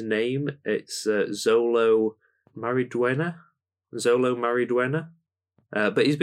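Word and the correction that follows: Xolo: You was close with the name. – How would you say it? – Solo Mariduena. – Mariduena. – Solo. – Solo, not Zolo. – X is an S at the start.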